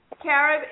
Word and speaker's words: Carib: Carib HR Radio. – Okay, how do I spell that? Carrot HR? – C-A-R-I-B. – Oh, Carib HR. – Right, radio. – Carib HR Radio.